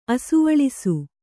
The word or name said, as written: ಅಸುವಳಿಸು